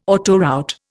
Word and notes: autoroute: Indian

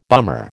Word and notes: bummer: American English

